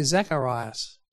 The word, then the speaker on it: Zacharias: And as it happened, our friend Zechariah, to whom we're introduced in verse 5, now you might notice some of your Bibles say Zechariah, which is a Hebrew pronunciation, and some of them might say Zacharias. And that is the Greek way of saying it, because it's been translated across.